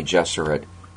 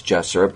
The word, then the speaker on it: Gesserit: he pronounces Duncan Idaho and Bene Gesserit correctly. And we know it's Gesserit because Frank Herbert said so, because it's supposed to rhyme with Jesuit, and that's a deliberate parallel.